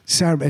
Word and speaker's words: Sarabande: Is that Varese Sarabande? – Sarabande. Is that how you say it? – Yeah, that's how you say it.